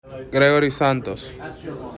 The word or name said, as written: Gregory Santos